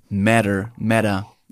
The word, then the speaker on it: Meta: Hey, Moment mal, aber Meta, also das finde ich einen sehr passenden Namen, weil Meta, ich finde Meta... Meta. – Ja, im Deutschen. – Meta. Ich finde, Mark Zuckerberg hat es im Englischen in dem Announcement-Video Matter ausgesprochen.